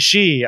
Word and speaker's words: sidhe: Is it Shay Quaza? Is that, did I pronounce that right? No, wait. – S-I-D-H-E? She? – She. Okay. – Why is there a D in there? – Because Celtic is weird. Gaelic is weird.